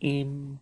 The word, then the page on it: im: Lwaitel